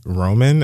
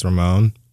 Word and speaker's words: Ramon: I think someone told me I said his name was like Roman, and I think it's Ramon.